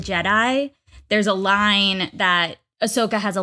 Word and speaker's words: Ahsoka: Wait, is that, am I saying her name right? Ahsoka? – Ahsoka.